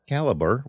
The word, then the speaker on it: Calibre: I am pronouncing it Calibre, as that is the way that Kovid Goyal, the creator of the program, pronounces it. I have heard several people call it Calibre, but I will honor the creator of the program and call it Calibre here.